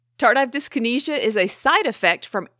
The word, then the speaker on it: tardive dyskinesia: That is up for debate, Gabe, because as I looked across the internet, there's a few different ways people pronounce this. Tardive dyskinesia, tardive dyskinesia, tardive dyskinesia. Today, we're mainly going to be saying tardive dyskinesia.